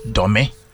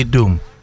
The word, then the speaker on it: Dome: Even here in Ghana, there is a place called Dome. – Dome. – If you don't take care, you will say Doom.